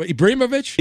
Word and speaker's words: Ibrahimovic: So Zlatan, I believe his last name, I'll try to say this properly, Ibrahimovic. Yeah. What's his name? Ibrahimovic.